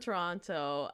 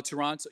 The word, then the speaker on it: Toronto: I know I always say Toronto because that's how we say it here, but the actual pronunciation is Toronto. You can say Toronto, but if you're out here, you probably say right without the T.